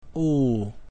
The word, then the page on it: U: Latin